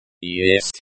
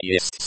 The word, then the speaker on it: есть: The e at the end of есть is very short and almost silent.